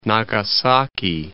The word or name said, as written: Nagasaki